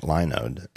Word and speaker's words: Linode: Now, I've been pronouncing it Linode, and I know it's a running gag on this show that I pronounce things wrong.